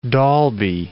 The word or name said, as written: Dolby